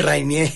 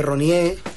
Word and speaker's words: Reinier: ¿Cómo lo pronuncias tú? – Reinier. – Pero sí, bueno, Reinier. Pero cada día suena mejor, ¿eh? – Cada día suena mejor. Una amiga mía le dice Ronier...